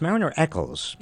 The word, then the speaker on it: Mariner Eccles: You open your book with the story of a man I'd never heard of, who served in the Roosevelt administration, Mariner Eccles. Am I pronouncing that right? – It's Mariner Eccles.